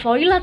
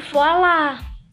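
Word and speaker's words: voilà: pendengar podcast Balik lagi sama gue, Voila Ada beberapa dari pendengar podcast yang komplain ke gue Bacanya tuh bukan Voila tahu, Tapi Voila Voila Iya sih bener,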